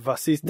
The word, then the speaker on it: was ist: And it's pronounced was ist. – Was means what. Was ist is more like what is.